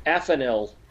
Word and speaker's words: Afanil: How do you pronounce the princess's name? – Afanil. – Thank you. – Kind of like daffodil is what I was thinking of.